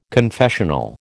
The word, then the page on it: confessional: American English